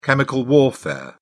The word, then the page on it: chemical warfare: British English